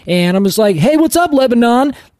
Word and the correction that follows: Lebanon: The DJ literally took the microphone from me and was like, it's pronounced Lebanon. And I was just up on stage.